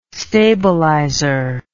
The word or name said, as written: stabilizer